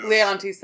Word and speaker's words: Leontes: Leontes, thank you, a toolbag.